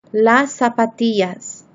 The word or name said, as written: las zapatillas